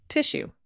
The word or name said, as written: tissue